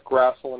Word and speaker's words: Grassl: And my name is Walt Grassel, and it's spelled G-R-A-S-S-L.